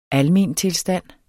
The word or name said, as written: almentilstand